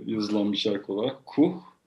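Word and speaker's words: Kûh: ismi Kuh ya da Kuh K-U-H ama U'nun şapkası var. Artık nasıl telaffuz ediyor? Kuh.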